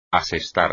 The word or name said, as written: asestar